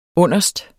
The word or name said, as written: underst